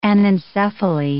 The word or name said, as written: anencephaly